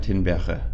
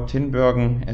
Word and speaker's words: Tinbergen: Jan Tinbergen, or Tinbergen as he is often pronounced in the English-speaking world.